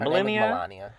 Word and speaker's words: Melania: Melania.